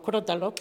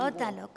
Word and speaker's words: crótalo: Se pronuncia así, algo que a mí me gusta aprender. – C-R-O, tal y como... – O-T-A-L-O. Crótalo. – Crótalo. – Vale, sí, perfecto. Ya no se me olvide. Con tilde. El lado, claro. Es esdrújula, ¿no?